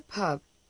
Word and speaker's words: pub: La U de pub tiene un sonido entre la O y la E.